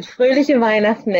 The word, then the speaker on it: Fröhliche Weihnachten: And Fröhlichen Weihnachten. I hope I said that properly. – You did. Fröhliche Weihnachten, everyone.